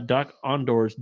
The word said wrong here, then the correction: Dok-Ondar: den of antiquities. Ondar. Ondar's, yeah. You said it right earlier.